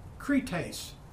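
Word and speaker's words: krites (κριτής): And it's K-R-I-T-E-S, pronounced krites, krites.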